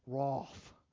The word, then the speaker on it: wrath: When I used to live in England, I could never say it like the English. Wrath, right? I always came out wrath. in my American way, right? God's wrath. They'd be like, no, it's the wrath of God, which sounded really terrible, right?